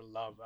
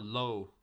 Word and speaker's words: Loeb: Yeah, or Love. I don't know how you pronounce it. Is it like Lowe or something like that? – Oh, Lowe. Yeah, it's Lowe.